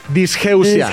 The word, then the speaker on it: disgeusia: Pues puede pasar ese tipo de cosas, la disgustia o La, ya ves que hasta la, pronunciación está disgeusia.